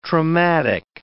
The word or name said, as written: traumatic